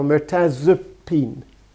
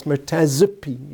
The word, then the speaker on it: mirtazapine: See, this is a weak vowel, mirtazapine. It's not a mirtazapine, it's mirtazapine, you'd pronounce it. It's pronounced weakly.